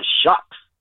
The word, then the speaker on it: Shough: And then I saw in the second round, they're talking about possibly drafting Tyler Shucks. – Yeah. – Louisville. – Yeah, he's actually gaining steam. Shuck. Even though it's spelled S-H-O-U-G-H. – It rhymes with suck.